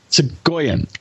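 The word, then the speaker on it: Soghoian: How do you pronounce his last name? – Segoyan.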